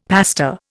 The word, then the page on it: pasta: Indian